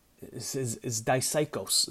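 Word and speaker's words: dipsychos (δίψυχος): This word that gets translated double-minded is dipsychos, is how we would pronounce it.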